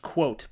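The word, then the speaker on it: Kvothe: I don't know what the hell that's supposed to be, Of course, in the book, they explain it's pronounced quote, but I guess, just by looking at that, it's like, well, why don't you just name him quote?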